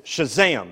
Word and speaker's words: Shazam: And you do have to say it like that. It's Shazam. It's Shazam.